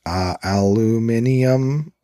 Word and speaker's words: aluminium: In both cases, the element symbol is AI, although Americans and Canadians spell and pronounce the name aluminum, while the British and most of the rest of the world use the spelling and pronunciation of aluminium.